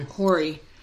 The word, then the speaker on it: Horry: Horry. And she goes, the H is silent.